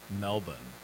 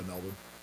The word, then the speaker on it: Melbourne: By the way, I didn't know until the other night that it's actually pronounced Melbourne and not Melbourne. – Yeah, Melbourne.